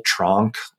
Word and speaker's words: Tronk: Tronk, like, rhymes with honky tonk, or it kind of sounds like trunk, which is not a great word, whether you're talking about an elephant or tree or the back of your car.